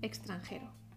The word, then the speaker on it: extranjero: Extranjero tiene cuatro sílabas. Ex, tran, ge, do. Extranjero. Cuatro sílabas. La sílaba fuerte que tienes que pronunciar con mayor intensidad es G. Extranjero.